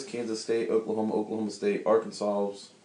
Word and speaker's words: Arkansas: As I'm driving through Arkansas and Oklahoma and Texas, do you know what was going through my head? You're pronouncing it wrong. It's Arkansas.